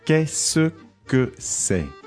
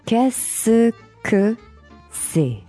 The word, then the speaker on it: Qu'est-ce que c'est: Qu'est-ce que c'est? – Qu'est-ce que c'est? – Mm-hmm.